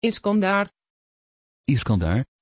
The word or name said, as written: iskandar